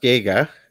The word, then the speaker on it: Giger: Actually, it's pronounced Giger.